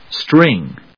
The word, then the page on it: string: stríŋ